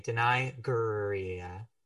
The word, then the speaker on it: Danai Gurira: Danai Gurira, I probably butchered that person's name. That is a Danai. Okay, Danai Gurira.